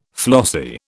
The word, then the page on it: flossy: British English